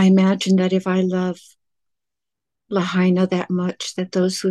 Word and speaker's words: Lahaina: You pronounce it Lahaina. – Lahaina. – I've always pronounced it Lahaina, right? – Yeah, Lahaina. That's right.